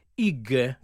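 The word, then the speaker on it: hygge: che è IG, che in realtà si scrive IG con la Y, e che è una parola intraducibile che significa essere soddisfatti.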